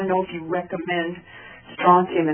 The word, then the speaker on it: strontium: Is that how it's pronounced? – Strontium is how it's pronounced.